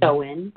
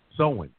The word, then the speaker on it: Samhain: Samhain. – How do you pronounce that? Samhain. – Samhain.